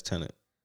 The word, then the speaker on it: Tenet: Tenet, probably. Yeah, Tenet, maybe. That's how you say it. – When he had like some gray suit on or something. Yeah, that's Tenet.